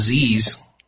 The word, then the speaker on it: Pzizz: I guess it's pronounced Z's. I guess is the way you're supposed to say it.